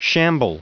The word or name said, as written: shamble